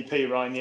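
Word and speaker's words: Perine: Is it definitely P. Ryan? – It is definitely P. Ryan, yeah.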